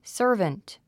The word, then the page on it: servant: sə’ː r vənt; サーバント